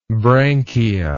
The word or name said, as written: branchia